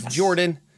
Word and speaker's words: Jordan: My name is Jordan, with a silent PH in the middle, and I'm a little loud, so I'm going to lower my mic.